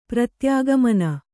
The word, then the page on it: ಪ್ರತ್ಯಾಗಮನ: Kannada